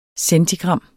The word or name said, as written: centigram